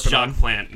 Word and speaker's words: Jacques Plante: Jacques Platant. – It was Jacques Plante. No one gets points. – Jacques Plante. – Serious? – Jacques Plante is how you say it.